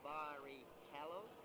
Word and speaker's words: Barrichello: My name is Ruben Barrichello, and it's pronounced Barrichello. It's C-H, and the Italian is a callo, and in Brazil as well.